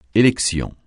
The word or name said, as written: élection